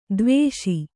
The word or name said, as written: ಧೀವಶ